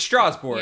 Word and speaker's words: Strasbourg: It's Strasbourg. Yeah. That's how you say it in French.